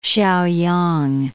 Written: Shaoyang

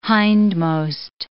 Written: hindmost

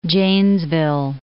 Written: Janesville

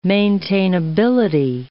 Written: maintainability

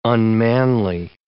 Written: unmanly

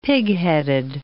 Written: pigheaded

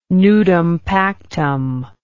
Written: nudum pactum

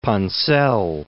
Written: Ponselle

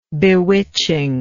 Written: bewitchingness

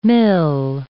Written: mille

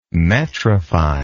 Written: metrify

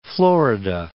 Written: Florida

